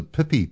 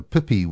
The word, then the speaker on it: pipi: didn't know what a pippi was. And that's spelt P-I-P-I, for those of you having trouble with my New Zealand pronunciation.